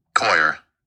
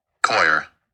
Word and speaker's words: coir: I know I always say that word wrong, so let's hear how it's pronounced. Coir. Coir.